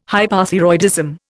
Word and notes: Hypothyroidism: Indian

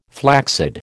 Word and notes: flaccid: American English

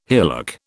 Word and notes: hillock: British English

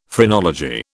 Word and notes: phrenology: British English

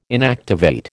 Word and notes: inactivate: American English

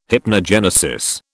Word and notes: hypnogenesis: British English

